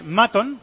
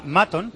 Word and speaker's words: Maton: Buen gol de Maton, con acento en la A, Maton, para el Gante.